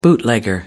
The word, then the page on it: bootlegger: British English